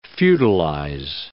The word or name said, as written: feudalize